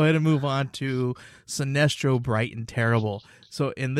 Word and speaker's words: Sinestro: It's Sinestro. Yeah, it's Sinestro.